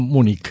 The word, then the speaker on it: Múnich: München, en castellano, Múnich. – Múnich, sí.